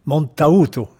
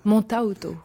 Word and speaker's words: Montauto: Montauto. – Montauto. – En italien, on prononce toutes les lettres.